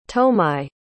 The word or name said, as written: tomai